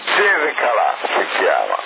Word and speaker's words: Calà: Comunque c'è, si chiama Calà, non Galà, era quello il problema, della G e della C.